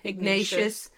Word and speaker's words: Ignatius: An extensive search of the St. Ignatius, or how did you say it? – Ignatius.